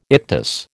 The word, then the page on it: ictus: American English